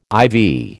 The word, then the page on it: ivy: American English